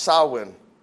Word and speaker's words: Samhain: It's spelled S-A-M-H-E-I-N, but it's pronounced Samhain.